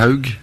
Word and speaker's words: Haug: Oscar Haug. – Et que moi j'aurais dit H. Haug ou quelque chose comme ça.